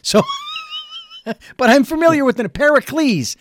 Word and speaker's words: Pericles: Because the title of the book is, I think it's called Pericles and Me. Did I get that right? – Pericles. – I beg your pardon.